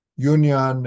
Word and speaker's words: Yunyan: This is Yunyan and Dawu. That's how you pronounce their names in No, that's in Chinese, and in Sino-Japanese, they pronounce the name Ungan and Dogo.